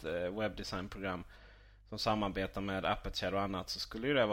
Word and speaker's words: Aperture: Ja, det hade ju kunnat passa rätt bra ihop med till exempel Aperture eller hur uttalar man det nu Gabriel? Aperture. Var det rätt?